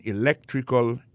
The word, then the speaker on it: electrical: I majored in electrical engineering. Electrical engineering. Ça veut dire accent tombé dans electrical là, tombé sur deuxième syllabe là. Electrical engineering. C'est sur deuxième syllabe là. Elect là, plus appuyé.